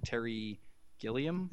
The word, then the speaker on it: Terry Gilliam: You know, especially because, like, I as soon as you told me about the wonderful, you know, everything that is Terry Gilliam.